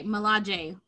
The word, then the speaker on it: Milaje: Like, they, I they pronounce sure the J. That. – They pronounce the J. – Okay, Milaje.